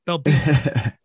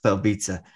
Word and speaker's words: Belbita: It looks like Belbitsa. That's not how it's pronounced. – Yeah. – I said it's like pizza at the end. Belbitsa. – Belbitsa.